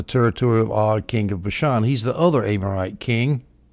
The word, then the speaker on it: Bashan: And by the way, I looked up how to pronounce Bashan. Some people say Bashan. I like Bashan better.